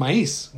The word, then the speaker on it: maíz: And then I realize, oh, they're talking about maíz, which is how we say it in Spanish.